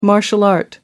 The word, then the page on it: martial art: American English